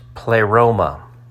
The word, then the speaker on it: pleroma: The Greek word is pronounced pleroma.